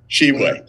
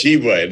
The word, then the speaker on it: Shibwe: Shibwe. Just Shibwe. There's no like tuh. It's not Yeah, just Shibwe. She.